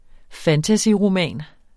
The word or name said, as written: fantasyroman